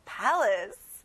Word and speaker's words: palace: A가 두 번 나오지만 첫 부분에만 아 소리가 나요. Palace.